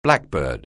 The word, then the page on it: blackbird: anglais